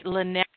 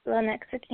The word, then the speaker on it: Lenexa: Let me see if I'm saying this right. Lenexa? – Yep, Lenexa, Kansas.